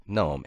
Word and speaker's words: GNOME: Sounds like gnome, N-O-M-E. But the GNU Network Object Model Environment, i.e. GNOME, is officially pronounced GNOME out of deference to GNUs, not Unix, G-N-U, which started the whole hard G thing.